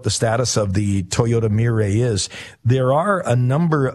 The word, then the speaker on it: Mirai: It's called a Toyota, I think it's pronounced Mirai. It's a Japanese word, M-I-R-A-I, a Toyota Mirai.